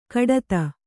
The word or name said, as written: ಕಡತ